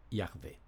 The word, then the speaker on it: Yahvé: Y, H, W, H, que creemos se pronunciaba Yahvé.